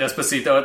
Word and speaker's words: Despacito: Expe Despaciado. – Oh, Despacito? – Despacito. – You say that wrong every time.